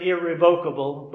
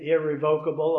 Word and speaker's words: irrevocable: But then there will be the irrevocable, and you might pronounce it irrevocable, but I understand both ways are accepted, irrevocable or irrevocable.